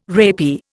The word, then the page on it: rapier: Indian